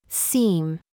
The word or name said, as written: FLEECE